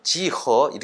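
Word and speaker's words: 지허 (几何): 그러니까 이제 기하를 중국말로 발음하면 지허 이렇게 되는데, 이게 이제 앞에 지오메트리의 앞글자인 그 지오를 음역한 셈이 되는 거죠.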